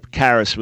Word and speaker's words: Charis: Yeah, probably Charis with a C-H pronounced like a K.